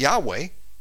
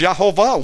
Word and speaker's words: Yehovah: It's not Yahweh. It's Yehovah.